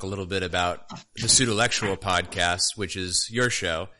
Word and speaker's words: Pseudolectual: I'm so happy that you're saying it correctly. Thank you. – What did I used to say? Because I know you... – Pseudo-intellectual. – That's right, yeah. Pseudo-intellectual.